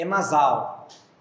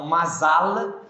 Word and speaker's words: mazal: Então, Masal é com L mudo. – M-A-Z-A-L. Não é Masal, é Masal. Mas a gente tem que aprender essa pronúncia aí. A pronúncia correta é com consoante mudo.